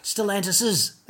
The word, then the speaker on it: Stellantis's: And it's Stellantis's apostrophe S, not just S apostrophe, it's S apostrophe S. Stellantis's, that's how you say it.